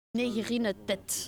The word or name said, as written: negre